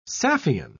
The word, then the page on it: saffian: British English